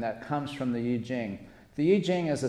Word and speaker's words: Yi Jing: How many are familiar with the Yi Jing? It's called the Book of Changes. Sometimes it's pronounced the I Ching.